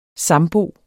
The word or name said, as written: sambo